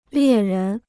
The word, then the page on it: 猎人: liè rén